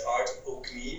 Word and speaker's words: Art O'Gnimh: Uh, it's not an easy one. It's Art O'Gnimh. It's oh. an Irish name, but trust me, outside of Ireland, it's never been pronounced correctly. So you, you did well.